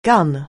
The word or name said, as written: gun